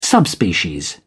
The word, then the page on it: subspecies: British English